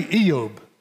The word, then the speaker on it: Job: We, we call him Job, but the Hebrew pronunciation of his name is actually Eob.